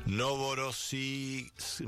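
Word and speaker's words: Novorossiysk (Новороссийск): Novorossiysk. A ver, vamos a pronunciarlo bien. Novorossiysk. Ahora sí, Novorossiysk.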